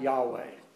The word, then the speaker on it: Yahweh: Now, how would you go about pronouncing YHWH? Closest most people come is something like Yahweh.